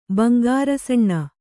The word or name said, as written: ಬಂಗಾರಸಣ್ಣ